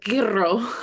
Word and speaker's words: gyro: My dad always says that we used to go to Greek festivals and he'd be like, I want this and a gyro. – That's a good little eye roll you got there. – Yes, that's how you say it. Gyro.